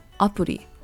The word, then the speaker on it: アプリ: For smartphone apps, the Japanese pronunciation is apuri.